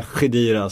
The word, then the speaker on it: Khedira: För de kommer ju nu förmodligen peta Khedira som jag fått lära mig att han uttalas.